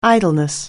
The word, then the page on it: idleness: anglais